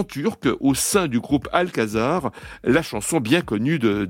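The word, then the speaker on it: Al-Qasar: Alors Alcazar, ça s'écrit A-L, trait d'union, Kazar, Q-A-S-A-R, mais ça se prononce comme le Alcazar, ça veut dire palais en arabe.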